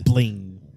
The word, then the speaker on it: bling: Bling. – With a hard G at the end. – Bling.